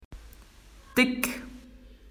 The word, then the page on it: thick: Australian English